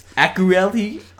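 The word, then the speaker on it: Akureyri: It's an imported beer called Ian Stock Toasted Porter from Akavuk, Iceland. – Okay, no, not even, that wasn't even close. – Akureli, Iceland.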